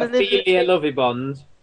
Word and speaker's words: Ophelia Lovibond: Ophelia Lovibond.